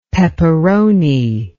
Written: peperoni

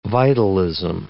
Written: vitalism

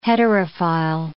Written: heterophile

